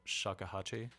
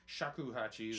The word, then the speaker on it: shakuhachi: And you said, is it pronounced shakuhachi? – Shakuhachi.